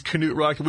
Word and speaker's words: Knute Rockne: And what we learned last week, the correct pronunciation is Knute Rockne. We've all been saying it wrong all these years.